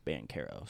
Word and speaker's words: Banchero: I was saying Banchero, but I think it's Banchero. So. – Yeah. The emphasis on the care.